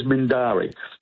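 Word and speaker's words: Mindarie: He was Mindaree is Mindari. Let be correct. Be correct the pronunciation there, which is up near Alkimos.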